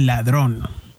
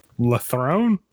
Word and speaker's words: Ladrón: Lathrone? – Ladrón. – Is that how you spell it? – Nope. – Well, how do you spell it? quit making me look like a freaking gringo. Oh, whatever. – L-A-D-R-O-N. Ladrón.